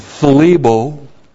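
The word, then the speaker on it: phlebo (θλίβω): This is the present passive participle of phlebo, and I don't have a lisp. It's phlebo. Plus the prepositional phrase and plus the dative singular of pos.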